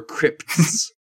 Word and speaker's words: Crips: Is it Crips or Crips? Is there a T in there? – No, or a Y. – No. Crips. – Oh. How do you spell it? It's just C R I P S. – With it, yeah, and then the S at the end.